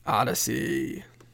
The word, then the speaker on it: Audacy: That's spelled AUDACY, Odyssey.